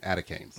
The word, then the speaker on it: Atacames: I found out that's the actual pronunciation, Atacames.